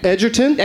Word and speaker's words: Edgerton: Joel Edgerton in that film, is that, am I saying his name right? His surname? – Joel? – Yeah. – Edgerton? – Edgerton.